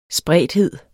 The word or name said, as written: spredthed